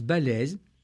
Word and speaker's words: Baslez: je vous recommande les ouvrages de Marie-Françoise Balèze. Ça s'écrit B-A-S-L-E-Z.